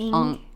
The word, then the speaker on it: Ng: but I think it's Ong. Kind of like Ong, like Aang from like Aang from The Last Airbender.